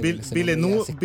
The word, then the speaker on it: Villeneuve: Eh, Dennis, ah, verdad, Dennis Villeneuve, no sé cómo se dice. – Villeneuve.